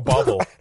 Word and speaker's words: bauble: A bauble. – Bauble. – You're putting some R's in there. – Like a decoration? – B-A-U-B-L-E.